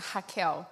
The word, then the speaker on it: Raquel: Yeah, in Portuguese it's Raquel. – So, but can be Rachel, is the same.